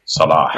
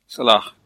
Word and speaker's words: Salah: I think you're absolutely right that we really should be looking at players with good injury records, which brings us nicely on to Salah, pronounced Salah. – Right, can we just pause here for that? Just, can you pronounce it again for those who can't pronounce it properly? – Salah. – So it's not Salah. Salah. It's not Salah. It's not Salah. It's Salah.